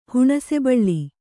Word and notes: ಹುಣಸೆಬಳ್ಳಿ: kannada

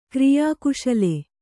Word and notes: ಕ್ರಿಯಾಕುಶಲ: kriyā kuśala; kannada

